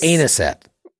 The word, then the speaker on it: anisette: I think it's anisette. – Anus? – I think it's anisette. – It's not the pronunciation that scares me. – Anisette? Am I saying that right? – Yeah, you are.